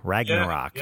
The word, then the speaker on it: Ragnarok: Ragged Ragnarok. – Ragnarok.